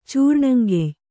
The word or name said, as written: ಚೂರ್ಣಂಗೆಯ್